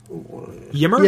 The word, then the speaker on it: Ymir: How would you say this name? Y M I R Ymir. Ymir. Yeah, that, that's Ymir. Ymir, right? Yeah.